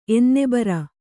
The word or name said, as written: ಎನ್ನೆಬರ